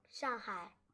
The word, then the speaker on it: Shanghai: How did you pronounce it? – Shanghai. – Shanghai. So you don't say the G? – No.